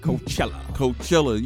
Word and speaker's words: Coachella: So anyway, next up go to Hip Hop Digest is Nas performing Illmatic in its entirety at, how you pronounce that shit? – Coachella.